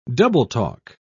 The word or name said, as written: double-talk